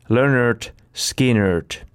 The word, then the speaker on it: Lynyrd Skynyrd: Alkusyksystä 1973 yhtye julkaisi nimeään kantavan debyyttilevyn, jonka kannessa muuten annettiin jo ohjeet foneettisesti, että miten se bändin nimi pitääkään lausua. Pronounced Lynyrd Skynyrd.